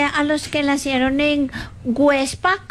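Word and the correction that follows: Güepsa: Perdón, ¿cómo? Huepsa. Websa. ¿Ah? Con diéresis.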